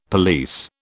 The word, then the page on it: police: British English